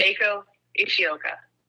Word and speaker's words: Eiko Ishioka: Eiko Ishioka.